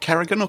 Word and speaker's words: Carrigan: Is it Carrigan or Corrigan? – Carrigan, with an A, yeah. Carrigan. – Welcome to the show, Joe. – I said it, and then I wasn't sure. – Nope, that's you said it right the first time.